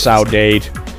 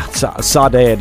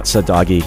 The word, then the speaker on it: Saudade: It's Saudade. And we're from Oregon. What do we know? – Yeah, Saudade is how I say it. Not Sadagi.